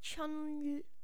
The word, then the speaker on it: Chelyabinsk: Chelyabinsk.